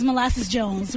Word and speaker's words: Molasses Jones: My name is Molasses Jones.